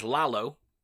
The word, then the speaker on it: Lalo: Alex Windham, who plays Lalo. – Is that how you fucking say his name? – Yeah. – Dude. – Like Lalo from Better Call Saul.